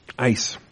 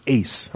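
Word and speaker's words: Ace (εἰς): Let's talk about E-I-S, okay? Ice, or ace. I guess I got to be consistent with my Thursday night Greek people. Ace, if we're going to use the A sound, the long A sound for the E-I diphthong, then we'll do that. Ace, E-I-S, Strong's number 1519.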